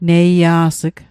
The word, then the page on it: neia's'g: Mi'gmaq